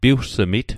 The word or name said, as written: piwgsmit